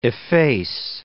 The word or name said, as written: efface